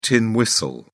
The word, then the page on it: tin whistle: British English